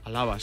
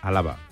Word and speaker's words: Alaba: Con acento en la segunda A. – Alaba, sí. – Alaba.